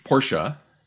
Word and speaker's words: Porsche: All right, this is Porsche, Porsche, if you want to say it correctly. Uh, I, I, I know that because I had one and I said Porsche and I was corrected many times.